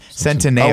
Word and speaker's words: Centineo: No, its name is pronounced Centineo. – Oh, wait. – Not Centineo. – Yeah, because he's Italian.